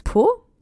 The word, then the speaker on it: Pau: Franzi kommt aber nicht aus Marseille, sondern aus Pau. Das schreibt man nicht wie den Hintern, sondern P-A-U.